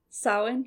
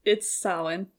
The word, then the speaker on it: Samhain: Samhain, yeah. – Amazing. So, it's spelled Samhain. – Samhain. – But it's Samhain.